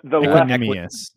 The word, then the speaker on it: Equanimeous: Equiminius, is that how you say it best? The Equiminius. – Equiminius.